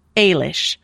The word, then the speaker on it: Eilis: So I know her name, even though it's spelled E-I-L-I-S. It's pronounced Eilish.